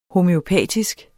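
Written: homøopatisk

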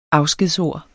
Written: afskedsord

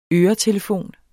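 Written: ØRETELEFON

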